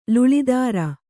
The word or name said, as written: ಲುಳಿದಾರ